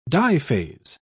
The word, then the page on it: diphase: British English